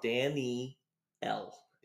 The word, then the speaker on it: Danielle: Danielle or Daniel? – What did I say? – Danielle. – Danielle. Now I feel like I'm saying I feel like I'm saying it right, but I can hear myself saying it wrong. – It sounds like to me like you're saying Daniel. – Danielle. – There you go. – Danny L. – Yes. – Danny L E.